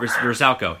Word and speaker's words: Vrsaljko: and pronounce it. – Vrsaljko? – Vrsaljko, yeah. – Okay. – You got it.